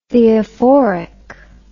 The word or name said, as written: theophoric